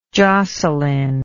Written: Joselyn

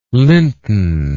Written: linton

